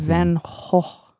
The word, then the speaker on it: Van Gogh: The Van beloved Van Gogh.